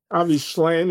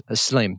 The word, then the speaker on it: Shlaim: Avi Shlaim, is that how you pronounce it? – Yeah, a Be'ivrit in Hebrew. I think that's how you'd pronounce it. Shlaim, yeah.